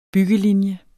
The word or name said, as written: byggelinje